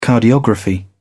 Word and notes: cardiography: British English

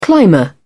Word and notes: climber: British English

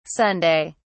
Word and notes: sunday: British English

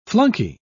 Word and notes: flunkey: British English

